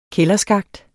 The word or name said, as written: kælderskakt